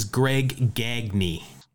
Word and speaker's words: Gagne: All right, so you're up to three even, and this is a guy, I always say his name wrong because there was a Dodger pitcher recently that has the same spelling but a different pronunciation. This is Greg Gagne.